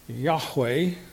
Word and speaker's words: Jehovah: The Hebrews didn't have a letter J. The Yod, the first letter there, really is our comes into English as a J. It's the same with the word Jerusalem. Jerusalem in Hebrew starts with the Yod. We don't say Yerusalem. We say Jerusalem. So Jehovah. Right, is how I think that should be pronounced.